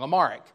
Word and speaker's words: Amharic: Amharic.